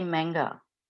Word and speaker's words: manga: Before we start, I was going to say, I was looking up before pronunciation, because in Australia, we tend to say manga. – Yeah, it's manga, because that's how they say it in Japanese.